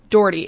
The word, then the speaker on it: Doherty: Doherty. It's like door, like regular door, and then it's like D, like the letter. Okay. That's basically how you pronounce it.